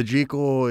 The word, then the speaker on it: Jekyll: What's the Jekyll? – The Jekyll is the British pronunciation of the title.